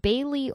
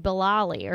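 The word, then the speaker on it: Bayly: The house known as the Bailey Oram House, which Bailey is spelled so weird. It's B-A-Y-L-Y. So every time I see it, I want to say like Bilali or something.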